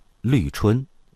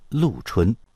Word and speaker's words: 绿春: 这是一个你在地图上难以找到的小县城，找到了你可能会把它读成绿春，因为这个字儿是绿颜色的绿，但其实它尊重当地方言，也就是这个字儿原本的古语发音叫陆。这个号称小春城的千山之县陆春，就如同桃花源一样安静地待在中国的边境线上。